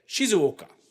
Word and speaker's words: Shizuoka: And I looked it up, and it says, oh, your tone shifts down. That's the way this is pronounced in this language. So the first half is Shizu, and the last half is Oka. And it's like supposed to be a Shizuoka. Like your tone is supposed to go down.